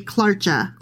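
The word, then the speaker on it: Klaartje: Phonetically, you would say Clarcha.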